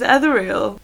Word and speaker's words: ethereal: Yeah, but I thought it was ethereal. – Yeah, it's ethereal. – I'm looking on Google. – Hang on. – How? – Yeah, I'm sure it's ethereal. – How to pronounce? – What? How are you saying it? – Ethereal. Right. I'm putting my laptop on full volume here. – Okay. – Ethereal. – Oh no! I was so confident. – I knew I was right.